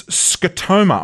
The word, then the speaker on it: scotoma: It's scotoma.